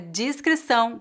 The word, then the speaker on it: discrição: A palavra discrição com I expressa algo discreto e sensato que não chama a atenção. Note que a pronúncia dessa palavra é feita com a vogal I no início.